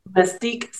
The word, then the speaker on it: Mastick: Mystique Senior Center. – How do you spell that? – It is M-A-S-T-I-C-K. – Mystique.